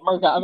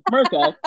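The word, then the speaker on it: 'Merica: Right, Merca. I apologize. I pronounced it wrong. Merca.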